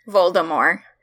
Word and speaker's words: Voldemort: Do you pronounce the T at the end of Voldemort?" – Yes. – Yes. – I mean, and I know that's not, like, the right way to do it, but... – What is the right way? – Voldemort. – To not pronounce the T, because it's based off of French. – And that's how they say it in Cursed Child, is without a T.